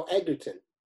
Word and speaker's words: Egerton: No, Egerton. – Egerton. – That's how you say it.